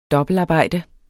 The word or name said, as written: dobbeltarbejde